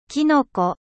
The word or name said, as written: Champigon